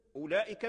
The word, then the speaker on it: أولئك: التوسط لا تظن أن هذا المد بسبب الواو ديال أولئك، لا، أولئك هذا الواو محذوف في اللفظ لا تلفظ به، لكنه ثابت في الرسم. إذا كتبت تكتب أولئك بالواو، هذا الواو لا تلفظ به، هو موجود في الخط دون اللفظ، موجود في الخط دون اللفظ. فإذا قرأت لا تقول أولئك، تقول أولئك أولئك أولئك، ما تقولش أولئك. لو قلت أولئك لحن قبيح إلى نطقت بالواو، لأن هذا الواو لا يلفظ به.